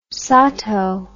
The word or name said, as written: Satow